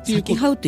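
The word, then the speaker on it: 幸はう: 言霊の咲きはう国あ咲きはうって読むんですねこれね幸せっていう字に「はう」って書いた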